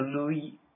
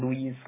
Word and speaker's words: Louis: 还发现 Louis 那个因为是法语词最后有个 S，它会读成 Louis Vuitton。他不知道那个 Louis Vuitton。 – 他不知道那个 S 不发音。